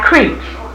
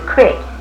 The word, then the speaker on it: creek: Now, this river down here is fed by creeks. And it's spelled C-R-W-E-K, and we pronounce it creek. We don't pronounce it crick.